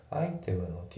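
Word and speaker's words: item: で、あと発音なんだけど、アイテムの T が濁ってアイテムですね。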